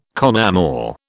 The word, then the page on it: con amore: British English